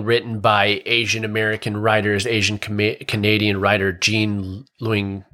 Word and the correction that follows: Gene Luen Yang: Oh gosh, I'm butchering his name. Gene Luen Yang, who is a very famous writer.